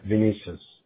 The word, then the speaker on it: Vinicius: Yeah, Vinicius.